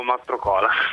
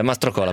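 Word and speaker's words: Mastrocola: Dimmi dove va l'accento, scusami. – Non lo so neanch'io. – Ah, benissimo. – Io dico Mastracola. – Mastracola, perfetto. E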